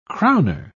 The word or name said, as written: crowner